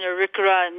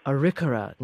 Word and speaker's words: Arikara: So can you say them correctly so everyone knows? – Yes, it is the Mandan Hidatsa and Arikara Nation. – Arikara Nation.